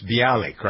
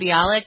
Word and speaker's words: Bialik: It's Bialik, right? Am I doing that correctly? – Yeah, you got the last name right, Mayim Bialik.